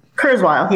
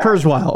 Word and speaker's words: Kurzweil: Kurzweil. Is that how you pronounce his last name? Am I getting that right? – Yeah, Kurzweil. Yeah, Kurzweil.